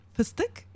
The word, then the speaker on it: fıstık: So I brought some bags of Turkish pistachios, what we call it, fıstık. And say that again. – Fıstık. – How do you spell that? – F-I-S-T-I-K.